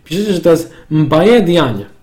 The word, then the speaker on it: Diagne: E, Pierwszym z nich jest e, Diagne, tak się chyba czyta? Diane. E, Diane, na pewno nie czyta się Diagne.